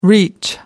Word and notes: reach: American English

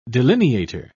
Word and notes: delineator: British English

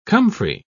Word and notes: comfrey: British English

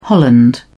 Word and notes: holland: British English

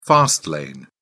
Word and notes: fast lane: British English

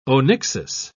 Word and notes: onyxis: British English

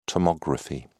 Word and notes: tomography: British English

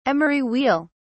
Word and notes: emery wheel: British English

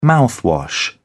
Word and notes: mouthwash: British English

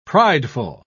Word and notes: prideful: British English

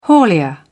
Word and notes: haulier: British English

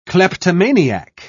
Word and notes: kleptomaniac: British English

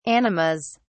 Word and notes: animas: British English

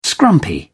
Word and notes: scrumpy: British English

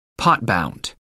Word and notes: pot bound: American English

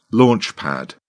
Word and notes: launch pad: British English

